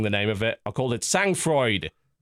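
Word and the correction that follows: Sang-Froid: No, Sang Froid, apparently.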